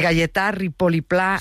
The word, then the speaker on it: Gaietà Ripoll i Pla: a ver si lo digo bien, de Gaietà Ripoll i Pla, sí, un mestre y pensador que tenía la seua escola a l'Horta de Russafa. – Perfecto. – ¿Lo he dicho bien? – Sí, sí, vale.